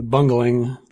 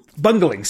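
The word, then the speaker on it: Bungeling: There's like no official pronunciation, but B-U-N-G. E-L, single L, I-N-G. – I always said the Bungling. – Bungling. See, I've always said Bungling as well, so that even though it's not really spelled like Bungling.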